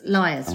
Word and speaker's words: Lyres: It's Liars. – Liars, right? – Yeah, it's a lie. – But it sounds like it's, because it's spelled L-Y-R-E-S, it sounds like it's a family name.